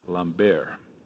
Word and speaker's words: Lambert: It's funny, we were not sure how to say the name, and the Finnish team said that it's pronounced in the French way, Lambert.